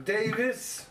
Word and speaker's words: Davies: Well, Ray Davies' name is really pronounced Davis, but he says he doesn't care as long as people are talking about him.